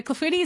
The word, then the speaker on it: clafoutis: C-L-A-F-O-U-T-I-S. – Oh, okay. So a silent I-S at the end. Clafouti.